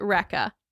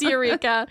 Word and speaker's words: Eureka: but I did learn in between recording that I was pronouncing Eureka, California incorrectly. – Yes, I think you did. – I called it Reka. – Yeah, I think so. – And it's Eureka. It's spelled ridiculously. So ignore me. It's Eureka.